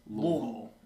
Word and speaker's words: Louisville: It, that kind of reminds me of when people in the South pronounce Louisville which is apparently the correct pronunciation. It is not Louisville. Mm-hmm. It's Louisville. Make like, sure there's you have a bunch a of stuff in your, mouth. your mouth. – Yeah.